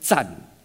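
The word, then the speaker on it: zan (战): This character is pronounced sen in Japanese and is equivalent to the Chinese word zan, zan zhen de zan.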